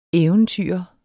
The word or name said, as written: eventyrer